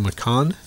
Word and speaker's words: Macaan: M-A-C-A-A-N. So Makan. Is that how you would pronounce it? M-A-C-A-A-N. – Yeah, that sounds about right.